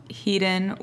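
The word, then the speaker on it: Hiden: Heaton was, that's how you pronounce it, right? – Yeah.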